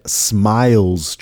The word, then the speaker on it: SMILES: its connection to mental health is the 2017 SMILES trial. SMILES, as in S M I L E S, literally as it's pronounced, smiles.